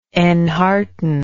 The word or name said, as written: enhearten